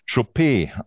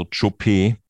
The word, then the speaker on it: Chopé: Das geht also von Zopp, sagt Pan Krischbin, über Schopp, sagt Marvin Schribbe, Schuppe, sagt Christian Knies, Schub, sagt Christoph Baluschke, der französische Rennkommentator sagte in dem letzten Grupperennen, wo sie gelaufen ist, Chopé, also mit einer Betonung auf dem letzten E.